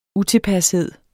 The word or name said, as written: UTILPASHEDEN